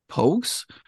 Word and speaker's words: Pogues: the Pogues. I don't know if I'm not saying that right now. – That is correct.